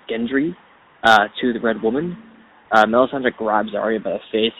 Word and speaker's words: Melisandre: Curious to see how Melisandre and...